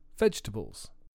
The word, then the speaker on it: vegetable: Comfortable swallows a syllable. Another common example is vegetable. Repeat after me.